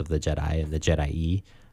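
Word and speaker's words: Je'daii: We often call it the Jedi. Just based on how it's spelled. But I think, Jordan, according to your research... – it's hard to find. I couldn't find any official John Ostrander like confirmations. Of how to pronounce it. I think from the best, and I agree with this interpretation, is there's two I's at the end. That would lead to like an E sounding at the end, Jedi E, and that kind of does make sense.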